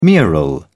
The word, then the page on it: mural: British English